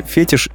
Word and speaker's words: фетиш: как правильно, фетиш или фетиш? Фетиш с ударением на последнем слоге, потому что это слово французского происхождения.